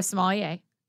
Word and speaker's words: sommelier: You are a sommelier. Did I say that correctly? – Yes, you did.